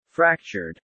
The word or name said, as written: fractured